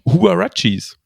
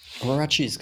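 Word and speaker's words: Huaraches: Und dann, und dann zufällig in einem Artikel gelesen, wie die denn tatsächlich ausgesprochen werden. Das sind Huarachis. – Huarachis, genau.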